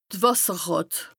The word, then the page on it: d' Wàsserràt: Bas-Rhin; Strasbourg